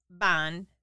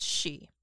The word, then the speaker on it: Banshee: So the word Banshee comes from the Irish B B-E-A-N, mm-hmm. pronounced Ban. – Yeah. – S-I, pronounced She, which translates as Woman of the Fairy Mounds.